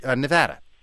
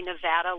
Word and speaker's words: Nevada: I am an assistant librarian and for the Lyon County, Nevada library system. And thank you for saying Nevada and not Nevada.